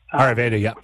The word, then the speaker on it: Ayurveda: And when you read about I think it's pronounced Ayurveda. – Ayurveda, yep.